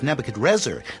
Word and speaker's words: Nebuchadrezzar: Yeah, well, actually, the story starts a few years earlier when Babylon's king, Nebuchadnezzar... – In fact, his name was Nebuchadrezzar. The N in Nezer is a mispronunciation.